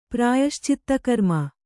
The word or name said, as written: ಪ್ರಾಯಶ್ಚಿತ್ತಕರ್ಮ